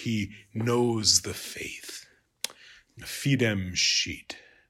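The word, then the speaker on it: scit: Lots of schoolboy jokes about this word, because as you can imagine, the third person singular in ecclesiastical Latin would be pronounced sheet.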